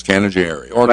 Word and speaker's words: Canajoharie: It's Canajoharie.